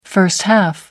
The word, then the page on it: first half: American English